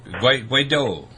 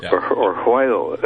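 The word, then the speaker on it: Guaidó: is Juan Guaido, G-U-A-I-D-O. Or Guaido. – Guaido. Yeah. – Or Guaido, if we pronounce the G like an H.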